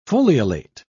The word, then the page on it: foliolate: British English